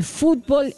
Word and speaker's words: fútbol: Se llama Noticiero, tiene una serie de noticias extrañas, alarmantes algunas, y hay un segmento dedicado al fútbol en Estados Unidos, que gracias a Dios lo denomina en el tema fútbol, con acento en la U, y no fútbol como en otros temas, y tampoco habla de soccer.